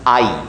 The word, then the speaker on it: Ai: We always pronounce the city's name as Ai, because, I don't know, you look at that name on the page, and that doesn't really work in English. The letter A followed by the letter I, like, that's not a word. How does that work? So we just say Ai. In Hebrew, it's pronounced Ai, which strikes me as funny because of what it means.